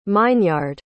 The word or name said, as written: meinyard